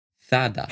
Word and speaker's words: Zara: Zara, und ich bleib dabei, wird tatsächlich Zada ausgesprochen.